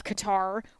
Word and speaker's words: Qatar: And to suggest that we have boots on the ground, but then also President Trump's executive order assuring the security of Qatar, or Qatar, however you want to pronounce it, is also very problematic.